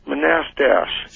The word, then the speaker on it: Manastash: Do you know where Manistash? – Yeah, it's pronounced Manastash.